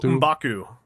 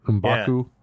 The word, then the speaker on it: Mbaku: M'Batu? Oh, Mbaku. – Mba- Mbaku.